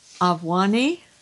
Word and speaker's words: Obwani: Avwani? – Yes. – Could you English spell that for me? – O-B-W-A-N-I. – O-B-W-A-N-I.